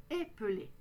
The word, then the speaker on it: épeler: Prononcez bien le « e » au début. Épeler.